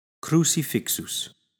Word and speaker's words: crucifixus: Crucifixus.